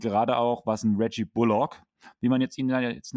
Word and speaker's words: Bullock: Und der hat sich jetzt offiziell in einem Interview, ich weiß nicht, ob es Postgame war oder sowas, hat er gesagt, er wird Bullock ausgesprochen. Nicht Bullock, so. sondern ah, das okay, O. Also das die, Betonung, o. die Betonung auf das O. – Genau. – Bullock.